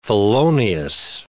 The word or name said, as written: felonious